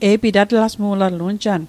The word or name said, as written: atlasmu'latl